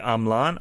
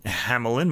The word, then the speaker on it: Hamelin: This is played by Marc-André Hamelin on the piano, or if you're American, Hamelin, Marc-André Hamelin. I've heard him pronounce the name both ways.